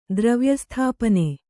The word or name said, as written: ದ್ರವ್ಯಸ್ಥಾಪನೆ